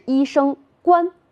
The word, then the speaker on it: 冠: 这个字的另一个读音则是一声冠，表示帽子的含义。